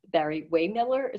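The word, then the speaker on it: Barry-Wehmiller: Barry Waymiller, is that how you pronounce it? – That's exactly how you pronounce it.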